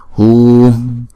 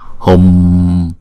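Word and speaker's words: hūṃ: Hùm, hùm. Chứ mình không đọc là hùng, đúng không? Hùng.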